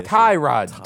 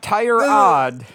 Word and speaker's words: tie rod: Tie rod. – I said it right. Anyways. – No. Nope. – Not tire rod. No. Tie rod.